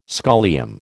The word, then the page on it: scholium: American English